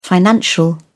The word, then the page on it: financial: British English